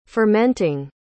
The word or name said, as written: fermenting